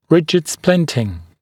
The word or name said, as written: rigid splinting